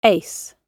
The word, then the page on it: ace: British English